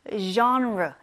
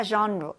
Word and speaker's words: genre: 이 장르, 장르는 영어로 발음을 할 때 genre 이렇게 합니다. 장르가 아니라 genre. 되게 발음이 이상하잖아요. 맞아요. 이 단어는 불어에서 온 단어이기 때문이에요. 그래서 G를 프랑스 사람들은 이제 z, z 이렇게 발음을 하거든요. 그래서 장르 이렇게 하지 않고 genre, genre 약간 Z 발음이랑 좀 비슷해요. 그래서 genre 이렇게 발음해 주시면 됩니다.